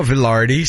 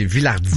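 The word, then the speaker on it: Vilardi: Il évoluait sur un trio formé de Mark Scheifele et Gabriel Villardi. Pardon. – Villardi. – Ouais, j'avais envie de dire Villardi comme les commentateurs français. – C'est pas Villardi, c'est Villardi.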